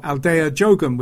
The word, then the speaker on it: Aldeia de Ogum: Joyce, who is Joyce Moreno, and the song Aldeia de Ogum, or Aldeia Jogum with the help of a pronunciation website.